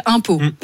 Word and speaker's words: Umpeau: Non, parce que la ville s'appelle Impau. – Oui. – Ouais, moi voilà. – Alors ça s'écrit comment? Ça s'écrit comme les impôts qu'on... – Non, ça s'écrit U-M-P-E-A-U.